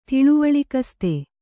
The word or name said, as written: ತಿಳಿವಳಿಕಸ್ತೆ